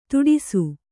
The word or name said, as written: ತುಟಿಸು